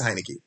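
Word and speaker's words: Heinicke: Heineke, it is Heineke.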